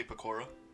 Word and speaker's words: Pekora: So yeah, I would have to say I like Gura like on top, and then Pekora. Mm-hmm. How do you pronounce it? – You could just say Pekora.